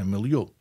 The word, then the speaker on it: milieu: In English, it's a milieu. – Milieu. – Yeah, so you're pronouncing it correctly.